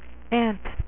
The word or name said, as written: Ant-Man